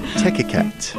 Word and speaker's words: Techiquette: Is that how you say it? – Techiquette.